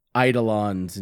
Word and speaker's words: eidolons: It's supposed to be a boxing simulator with uh, these titans and uh, eidolons and shit like that. I said it right this time, right? – Yes.